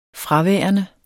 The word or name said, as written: fraværende